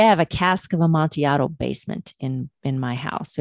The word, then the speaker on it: Amontillado: And I am saying Amontillado because that would be the Spanish pronunciation where two L's make a Y.